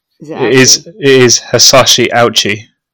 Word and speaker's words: Hisashi Ouchi: Okay, I've gone to a pronunciation site, and it is Hisashi Ouchi.